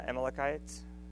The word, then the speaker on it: Amalekites: That's when Saul was fighting against Amalekites, that's how you say it.